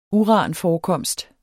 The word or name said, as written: uranforekomst